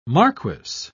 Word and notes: marquess: British English

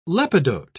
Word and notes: lepidote: British English

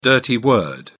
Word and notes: dirty word: British English

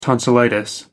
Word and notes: tonsillitis: American English

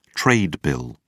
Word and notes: trade bill: British English

